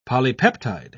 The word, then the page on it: polypeptide: British English